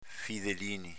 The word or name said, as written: fidelinin